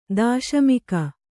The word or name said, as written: ದೇಶಮುಕಿ